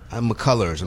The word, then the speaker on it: McCullers: Oh, you did McCullough. – McCullers. I'm sorry, oh, okay.